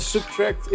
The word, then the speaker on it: SBTRKT: Zijn naam schrijf je als S-B-T-R-K-T. Maar spreek je uit de Subtract.